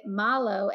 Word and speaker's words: Marlo: And our daughter Marlo, it's spelled Marlo, but we made it Malo, M-A-L-O, because that's how Australians say her name. So they pronounce it Malo.